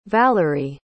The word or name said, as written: valorie